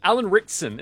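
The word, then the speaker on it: Alan Ritchson: Alan Rickson, is that how you say his name, Michael the Movie Guy? I always forget. – Yes.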